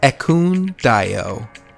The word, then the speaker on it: Ekundayo: And if you'd like to walk with me, I'm Scorpiyogi, S-C-O-R-P-I-Y-O-G-I. That is Scorpiyogi. And for those who are always saying that they can't pronounce my last name, other than me saying at the beginning of literally every show, take a moment to say it with me. It is Ekundayo.